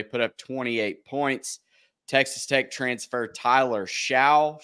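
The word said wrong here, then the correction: Shough: I'm not sure how you say it. What? – Shuck.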